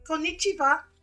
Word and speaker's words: konnichiwa: We say konnichiwa. – Konnichiwa.